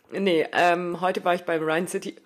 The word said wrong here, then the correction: Rhein City Run: Ach, ich wusste, dass das passiert, ne? Rhein City Run. Ich kann das nicht aussprechen. Rhein City Run.